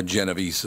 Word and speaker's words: Genovese: Her name is Kate Genovese. Or Genovese is how she pronounces it, G-E-N-O-V-E-S-E.